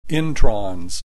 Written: introns

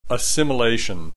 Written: assimilation

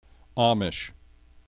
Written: Amish

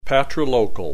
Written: patrilocal

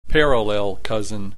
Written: parallel cousin

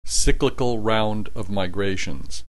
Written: cyclical round of migrations

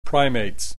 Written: Primates

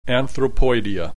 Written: anthropoidea

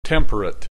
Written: temperate

